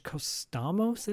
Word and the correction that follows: Cosmatos: I'm butchering his last name, but Costamos, I think is his name. Regardless, you know, that movie had so much going for it. Oh, Cosmatos, sorry. George Cosmatos.